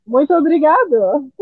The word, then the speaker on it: Muito obrigado: Muito obrigado. – Great. Muito obrigado.